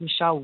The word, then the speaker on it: Michele: I think it's because my name is spelled with Michele one L. – Yeah. – Well, people look for Michele Macfarlane and then can't find it. So yeah. It is Michele with one L.